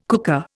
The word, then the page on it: cooker: Indian